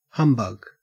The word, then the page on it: humbug: Australian English